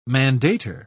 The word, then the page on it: mandator: British English